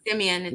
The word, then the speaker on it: Simeon: Simeon or is it Simon? – Okay, there's Simeon.